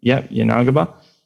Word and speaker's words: Yanagiba: Yanagiba? – Yanagiba.